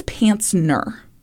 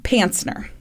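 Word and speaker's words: Pancner: One of the psychiatrists who examined him was Ronald Panzer, which I really enjoy that last name. – Mm-hmm. – Actually, I think it's Pantzner, but it's spelled with a C P A N C N E R, Pantzner.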